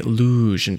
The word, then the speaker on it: Luzhin: I should say Luzhin Defense because that's how the author explains it should be pronounced.